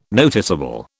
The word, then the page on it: noticeable: British English